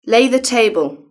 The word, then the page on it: lay the table: British English